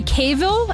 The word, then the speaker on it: Cavill: Henry Cavill, am I saying his name right? – Yeah.